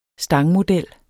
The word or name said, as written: stangmodel